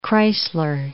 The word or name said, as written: Kreisler